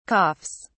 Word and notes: coughs: British English

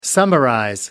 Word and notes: summarize: American English

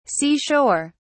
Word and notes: sea shore: British English

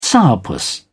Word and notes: sourpuss: British English

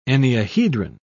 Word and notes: enneahedron: British English

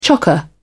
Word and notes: chocker: British English

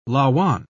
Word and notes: lauan: British English

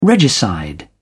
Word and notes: regicide: British English

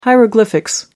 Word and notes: hieroglyphics: American English

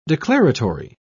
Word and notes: declaratory: British English

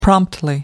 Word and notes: promptly: American English

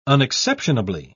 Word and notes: unexceptionably: British English

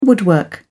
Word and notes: woodwork: British English

